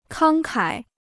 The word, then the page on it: 慷慨: kāng kǎi